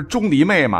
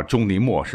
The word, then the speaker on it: 钟离昧: 不是钟离昧吗？实际上，这个古代发音应该是墨啊，很多人把它读成昧，或者把这个字呢就错读为味道的味啊。实际上叫钟离墨是比较准确的。